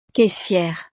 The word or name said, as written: caissiere